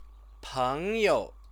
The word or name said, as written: pengyou